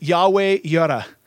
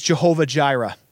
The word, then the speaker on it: Yahweh Yireh: And tonight, we're going to look at one in Scripture where God is referred to as Yahweh Yireh. Or the more common pronunciation is Jehovah Jireh.